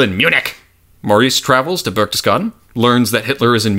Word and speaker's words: Berchtesgaden: He thinks that the hitman is in Berchtesgaden. Did I pronounce that right? – I think you did. – Oh my god! Berchtesgaden!